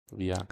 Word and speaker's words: Evija: Well, Avija? – Avija? E-V-I-J-A. Avija? – Avija.